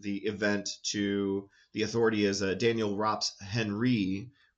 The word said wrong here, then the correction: Henri: which is more of a French name the way it's spelled. I said Henry and it would be Henri,